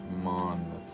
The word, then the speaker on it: Monmouth: Monmouth.